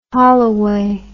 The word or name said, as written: holloway